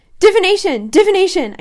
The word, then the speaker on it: divination: I'm like, wow, that's a dingy place to meet a divinization teacher. Divination! I can't say that correctly.